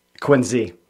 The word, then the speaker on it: Quincy: And so even though it's spelled Quincy with a C, how do you say it properly? – Quincy. – Quincy. Exactly. Yeah. With a Z.